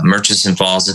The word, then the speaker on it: Murchison Falls: Yeah, Murchison Falls.